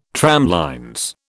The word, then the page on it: tramlines: British English